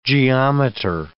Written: geometer

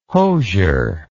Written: hosier

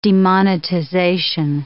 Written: demonetization

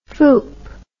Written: foop

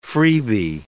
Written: freebie

